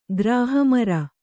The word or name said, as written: ಗ್ರಾಹಮರ